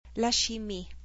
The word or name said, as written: La chimie